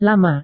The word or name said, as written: lama